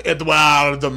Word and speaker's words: Eduardo: And since now apparently we're doing middle names, my name is Donald Eduardo Marshall, and I too am a geek. – Do you have to say it like that? – I do, because that's actually how it's pronounced.